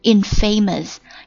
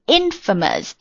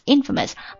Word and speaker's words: infamous: It was infamous，首先看这个 infamous 很容易读成 infamous，因为它是 famous 前面加了一个 in，变成了一个否反义词，但是呢它重音在第一音节上面。 infamous， infamous， I-N-F-A-M-O-U-S， infamous 形容词，表示无耻的、声名狼藉的、臭名昭著的等等意思。